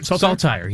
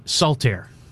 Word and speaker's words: Saltire: Emphasis on the first syllable. – Unless you're Scottish. – And then what do you say? – Saltaire. – Saltaire. – Yeah, like it's salt air.